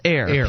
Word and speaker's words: Iyer: I believe it's pronounced Air.